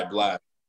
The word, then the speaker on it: Blxst: This song called Overrated by Blast. So Blast is spelled BLXST, and the song's called Overrated.